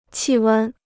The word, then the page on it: 气温: qì wēn